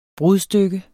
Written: brudstykke

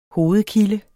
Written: hovedkilde

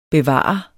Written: bevare

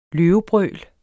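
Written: løvebrøl

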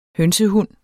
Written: hønsehund

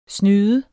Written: snyder

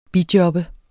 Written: bijob

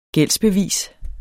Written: gældsbevis

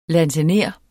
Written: lansener

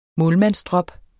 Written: målmandsdrop

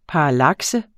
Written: parallakse

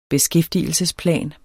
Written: beskæftigelsesplan

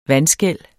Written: vandskel

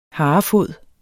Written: harefod

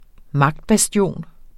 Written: magtbastion

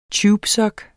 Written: tubesok

